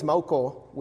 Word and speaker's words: Maucó: My last name is Mauco, which is kind of hard to pronounce for almost every American that I know.